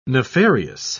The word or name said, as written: nefarious